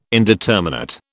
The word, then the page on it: indeterminate: British English